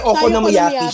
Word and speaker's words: Okonomiyaki: Nakakainis kasi yung isang extra syllable tapos hindi ko na siya masabi. Kasi Okonomiyaki siya. Ayun, yes.